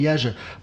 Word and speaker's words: Jerzy: I'd say to the Polak, if it really makes you happy, go ahead and call me Jerzy. Or Jurek or whatever, but... If some English guy tries to say Jurek or whatever, they always butcher it. So I'm like, just call me Jersey.